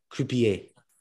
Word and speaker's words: Croupier: C-R-O-U-P-I-E-R. Croupier.